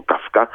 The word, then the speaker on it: Kafka: Jeden se jmenuje Kafka a píše se ale v češtině se to i v němčině vyslovuje samozřejmě jako Kafka a trpí tím,